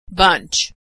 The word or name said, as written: bunch